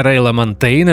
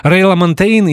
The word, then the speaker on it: Ray LaMontagne: Я знаю, что есть несколько вариантов произношения фамилии этого музыканта я использую, наверное, такой более французский, может быть, потому что это первый вариант, который я услышал. Рейла Монтейн и его пластинка, появившаяся летом в июне 2020 года, которая называется Manavision.